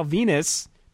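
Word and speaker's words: Venis: Masters defeated an unknown Hurricane Rosie and Val Venus. – Rhymes with penis.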